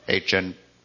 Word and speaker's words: Achan: And then there was one that said, this is how you pronounce it, Achan. So, anyway, that's how you say it.